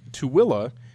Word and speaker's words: Tooele: And Tooele is spelled T-O-O-E-L-E. Not a single W in there.